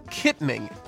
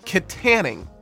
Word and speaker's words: Kittanning: in which I called the town at play Kittening instead of Kittanning over and over and over again.